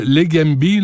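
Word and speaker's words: Leqembi: Avez-vous l'impression que vous me dites c'est le Legambi, là, c'est comme ça qu'on le prononce.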